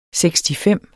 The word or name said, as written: sekstifem